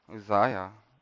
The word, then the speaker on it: Uzziah: Now, just to give you a little bit of background, is this is, I don't know, how do you say that king's name? Uzziah. Is it like that? Just like that.